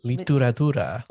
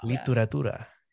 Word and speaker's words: Literatura: Literatura. It's like literature, but in Spanish. – Curatura. – Literatura.